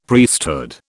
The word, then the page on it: Priesthood: British English